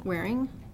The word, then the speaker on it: Waring: Kristen Waring, W-A-R-I-N-G.